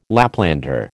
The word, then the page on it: Laplander: American English